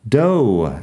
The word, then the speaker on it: dough: Tiene un par de usos interesantes y también la pronunciación es interesante porque termina en GH. Dough.